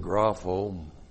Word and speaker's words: grapho: All right, well, you've seen the word grapho many times. G-R-A-P-H, long O, grapho.